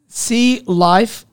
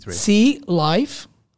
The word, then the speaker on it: C Life: So it's C Life, all capital, 63. C Life, like Cliff kind of.